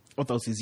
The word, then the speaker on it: orthoses: Orthoses. Did I say it right again? – Orthoses, yes.